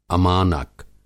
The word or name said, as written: अमानक